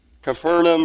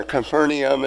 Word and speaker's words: Capernaum: Capernaum or Capernaum, it's pronounced both ways.